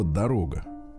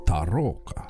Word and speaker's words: дорога: Оказывается, немцы не могут произнести правильно по-русски слово дорога. – А как они произносят его? – Тарока.